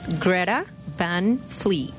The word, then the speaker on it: Greta Van Fleet: Greta Van Fleet.